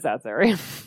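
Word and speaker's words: Atzerri: I have to eat a little crow just before you go on, because I purposefully pronounced At-Zeri as As-Terry somewhat earlier, like a few minutes ago, because I thought that you had spelled it wrong in the notes. I remembered it being As-Terry. You're right, it is At-Zeri.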